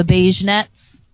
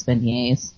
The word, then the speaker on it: beignets: He goes, yeah, I'm going to get 6 of the beignets. And he was like, is that how you say it? And the lady was just, like, not amused. She was like, it's beignets.